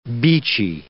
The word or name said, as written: beachy